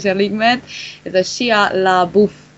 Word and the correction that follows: Shia LaBeouf: Shia LaBeouf. – Shia, Shia LaBeouf.